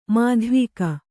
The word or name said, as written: ಮಾಧ್ವೀಕ